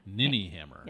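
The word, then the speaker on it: ninnyhammer: Ninny hammer. – Mini hammer. – Ninny. – Ninny hammer.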